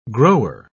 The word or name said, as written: grower